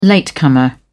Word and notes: latecomer: British English

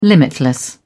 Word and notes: limitless: British English

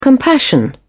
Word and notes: compassion: British English

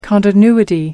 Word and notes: continuity: American English

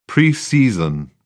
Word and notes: preseason: British English

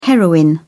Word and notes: heroine: British English

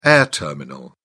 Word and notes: air terminal: British English

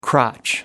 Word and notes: crotch: American English

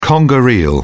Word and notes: conger eel: British English

